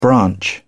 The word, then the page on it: branch: British English